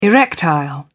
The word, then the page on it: erectile: British English